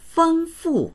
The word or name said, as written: feng1fu4